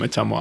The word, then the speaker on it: Ottumwa: I apologize because I'm pretty sure I butchered the pronunciation. – I have two friends from Ottumwa. Actually, no, you pronounced it right.